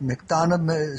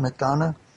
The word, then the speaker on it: McDonough: Is it McDonough? Is that the name of your town, McDonough? – Yes, correct.